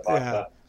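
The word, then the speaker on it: Wajda: Vajsha? How do you say his name? – W's a V, but yeah, Vajsha.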